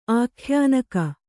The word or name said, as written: ಆಖ್ಯಾನಕ